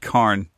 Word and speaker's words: Karn: Richard Karn. – Richard Karn. – Yeah. Badass name, actually. – Yeah, that is a cool name. – Very, very close to corn.